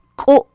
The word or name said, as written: k'u'